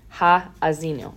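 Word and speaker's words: Ha'azinu: Now the wild part is this song is actually known as the Hazino. Ha-azino. I'm sorry, I said that wrong.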